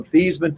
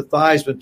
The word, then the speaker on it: Theismann: But we think by just simply changing the pronunciation of your last name from Theismann to Theismann to rhyme with Heisman, we can get you that trophy.